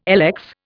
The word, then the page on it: lx: Indian